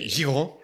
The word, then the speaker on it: Giraud: That's spelled G-I-R-A-U-D. – Yeah, Giraud. – How do I pronounce that? – Giraud.